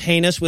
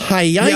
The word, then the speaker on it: heinous: also, this is such a weird no, but why do dumb people always pronounce heinous with so many syllables? Hyena. – yeah, yeah. was I believe how he said it.